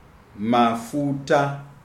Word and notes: Mafuta: Shona

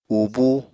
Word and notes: ubúu: Apatani

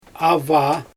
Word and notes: avaa: Xyzyl

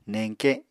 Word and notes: neñke: Apatani

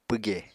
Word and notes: pwge: Apatani

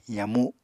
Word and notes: yamu: Apatani

